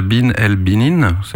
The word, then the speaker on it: Bin El Binin: Alors, en 2014, donc, t'as lancé ce projet chanson, et puis en 2018, il y a eu l'EP Bin El Binin, je prononce bien ? – Oui, tout à fait, très bien.